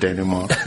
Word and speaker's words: denouement: Patients look at me like, did you really use the word denouement? – Yes, I did. And pronounce it correctly? – Yes, you did.